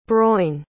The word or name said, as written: broin